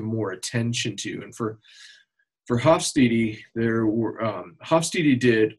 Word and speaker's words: Hofstede: I said his name wrong, I think 10 different ways in the podcast. Hofstede. So it's GeertHofstede.